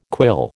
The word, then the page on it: quill: American English